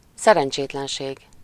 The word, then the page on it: szerencsétlenség: /ˈsɛrɛnt͡ʃeːtlɛnʃeːɡ/